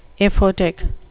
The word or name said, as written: aphotic